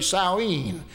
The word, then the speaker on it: Samhain: And they called it, I pronounce it Samhain, but that's not the way they say it. They say Samhain.